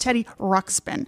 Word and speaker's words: Ruxpin: And do you know, until about 5 years ago, I pronounced it Teddy Rubskin? I know! – Yeah. – Somebody was like, how do you say that? I'm like, oh gosh, I have to really... Okay, that's... Teddy Ruxpin.